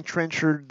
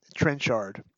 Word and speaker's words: Trenchard: It occurs to me that I've been pronouncing Trenchard the American way rather than the British way, Trenchard.